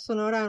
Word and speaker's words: Sonora: Your pronunciation of Sonora and Oaxaca are perfect, by the way.